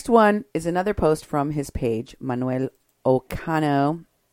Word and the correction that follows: Ocaño: Ocaño is actually his name, how you pronounce it.